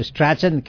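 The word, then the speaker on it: Strachan: इसको स्ट्रैचन कहते हैं, स्पेलिंग अगर आप देखेंगे तो स्ट्रैचन कहते हैं। – अदरवाइज स्ट्रैचन ही समझिए, STRACHN STRACHN STRACHN स्ट्रैचन।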